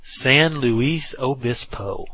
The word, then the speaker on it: San Luis Obispo: then he gave me the correct pronunciation. San Luis Obispo.